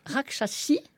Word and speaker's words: Rakshasi: Vous le dites comment au féminin? – Rakshasi pour les femmes.